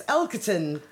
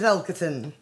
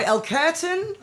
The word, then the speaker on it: Elkerton: Definitely not the surname that you want to call, but yeah, it's Elkerton. The surname is Elkerton. – Now, that's easy for you to say. – However, if you can't pronounce it, try Elkerton, like a pair of curtains in Spanish.